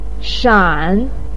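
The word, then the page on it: shan: tone 3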